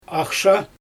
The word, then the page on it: axsha: Xyzyl